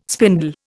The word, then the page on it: spindle: Indian